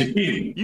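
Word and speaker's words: Shaqiri: You pronounced it right. Shakiri. You pronounced it right.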